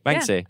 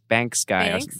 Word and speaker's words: Banksy: Banksy. – Yeah. Uh-huh. In the thing, they're calling him Banks Guy.